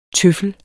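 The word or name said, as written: TØFFELS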